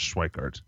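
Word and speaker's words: Schweickart: It's spelled weird, but it's Schwickart.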